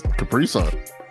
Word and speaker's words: Capri Sun: Capri Sun. All one. You put a real emphasis on sun.